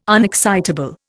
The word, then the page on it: unexcitable: Indian